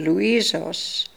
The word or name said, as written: Looisos